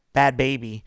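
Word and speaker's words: Bhad Bhabie: We're agreeing that's how it's said, right? – Yeah, it's Bad Baby. – Okay, Bad Baby. I don't know why she has to spell it like that, but she does.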